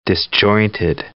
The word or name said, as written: disjointed